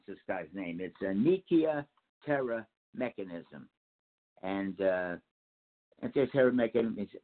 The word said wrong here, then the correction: Antikythera: The Antikythera device, it's called.